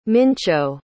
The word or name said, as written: mincho